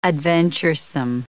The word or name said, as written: adventuresome